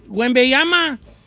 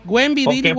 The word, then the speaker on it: Wembanyama: Don Cheto, a ver, dígame, ¿cómo se dice? – Wembanyama. – Wembanyama. – Wembanyama, como le dice todo el mundo.